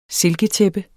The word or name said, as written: silketæppe